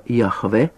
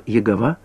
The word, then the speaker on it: Яхве: Это написанное слово «Яхве», неправильно произносимое иногда как «Егова», происходит от корня слова «жизнь» и означает «подлинно живущий» или «сущий», по-гречески «оон».